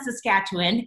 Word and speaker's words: Saskatchewan: I was raised in Regina, Saskatchewan. And yes, I said the name right.